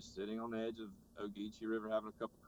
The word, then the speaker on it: Ogeechee: Okay, so let's talk a little more about some of your songs, like Ogeechee? How do you say that? – Ogeechee? – Yeah, okay.